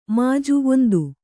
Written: ಮುಜ್ಜು

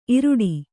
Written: ಈರಡಿ

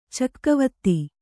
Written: ಚೆಕ್ಕೆವತ್ತ

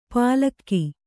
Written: ಪಲಕ್ಕಿ

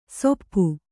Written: ಸೊಪ್ಪು

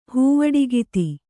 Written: ಹೂವಡಿಗಿತ್ತಿ

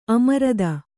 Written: ಅಮರ್ದು

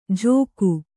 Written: ಝೋಕು

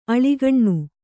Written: ಆಳೋಕನ

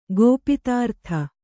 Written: ಗೋಪಿತಾರ್ಥ

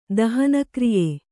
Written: ದಹನಕ್ರಿಯೆ

